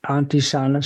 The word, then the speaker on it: Antisana: Antisana Sky.